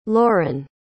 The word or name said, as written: loren